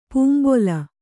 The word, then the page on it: ಪಂಬಲ್: pambal